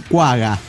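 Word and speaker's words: quagga: En los zoológicos está la común, la Equus guapa. ¿Guapa? Ah, no, cuaga.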